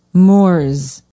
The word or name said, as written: Morse